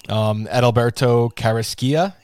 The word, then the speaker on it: Edilberto Carrasquilla: You have Edilberto Carrasquilla.